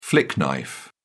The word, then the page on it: flick knife: British English